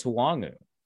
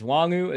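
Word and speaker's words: Nwangwu: Did I say that correctly? – I think it's Wongwu, which there's a W there, but is it silent? – There is. It sounds like they don't want us to say it on the pronunciation guide. So it's Wongwu, as far as I know.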